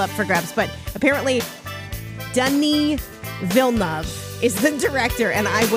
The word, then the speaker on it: Denis Villeneuve: I thought it was Denis Villeneuve. No, it's Denis Villeneuve.